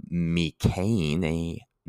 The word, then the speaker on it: mēkhanē: But in this case, biomechanics, mechanics come from the mēkhanē. It's spelled M-E-K-H-A-N-E with two long marks, macrons over the E's. That's why I tried to make those E's long.